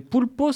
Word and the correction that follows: Poulbots: ça se dit? – Ça s'écrit, ouais, Poulbo. – P-O-U-L-P-O? – P-O-U-L-B-O-T-S. Poulbo. Ah, parce que sinon, je ne trouve pas. – Tu chercheras. – Poulbo, ah, d'accord, comme beau, d'accord.